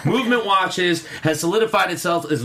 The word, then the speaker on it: MVMT: Movement watches pronounced very coolly. Very few vowels used in that.